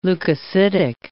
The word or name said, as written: leukocytic